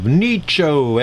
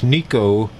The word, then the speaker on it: Nicho: Yeah, I perform as a guitarist and folk singer under the name of Nicho, N-I-C-H-O. A lot of people want to pronounce it Italianly as Nico, but it simply isn't that. It's Nicho.